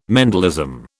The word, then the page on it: Mendelism: British English